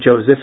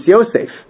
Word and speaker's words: Yosef: There's no name Joseph, it's Yosef. Because the J in Latin doesn't make a J sound, it makes a Y sound.